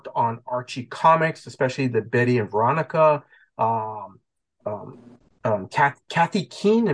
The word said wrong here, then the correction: Katie Keene: Am I pronouncing that one correctly? – Katie Keene. – Katie Keene. Yep.